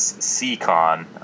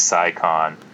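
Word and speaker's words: Scicon: I believe it's pronounced, I saw on Slow Twitch, as C-Con. It looks like Psy-Con.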